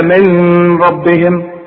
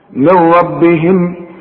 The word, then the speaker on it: من ربهم: شوف من ربهم، اوعى تقول لي بقى من ربهم او من رب، لا، حشبه كلمين مع الراء، ودي سهلة طبعا، اسقط النون دي من النطق. من ربهم،